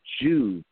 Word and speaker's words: Joovv: They're called Joovv, and that's spelled J-O-O-V-V.